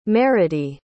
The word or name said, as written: meradee